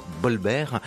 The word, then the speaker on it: Volver: la lettre V en espagnol se prononce plutôt comme un B. Exemple, le titre du film de Pedro Almodovar, Volver, se dit plutôt Bolbert.